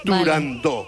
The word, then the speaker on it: Turandot: Para que quede claro, es Turandot.